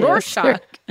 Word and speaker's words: Rorschach: It is a bit of a Rorschach. Sorry, I can't pronounce that word. I've never been able to. – Rorschach.